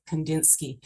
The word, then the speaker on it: Kandinsky: Kandinsky. Wassily. I don't know how to pronounce it. Kandinsky. – Yeah, that's right. – Is it right? – Yeah.